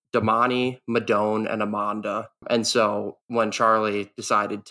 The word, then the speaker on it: Emonda: It's Amanda, E-M-O-N-D-A. Yeah, so no, but close.